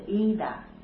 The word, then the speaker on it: idha: Bante, I-D-H-A, you pronounce Ida.